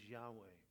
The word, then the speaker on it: Yahweh: Now remember, Jehovah, the way the Hebrews pronounce that is Yahweh, because they don't pronounce the vowels.